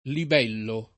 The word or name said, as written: libello